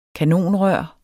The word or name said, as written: kanonrør